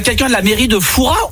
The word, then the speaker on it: Fourras: Eh bien écoutez, on dit Fourras. On ne prononce pas le S.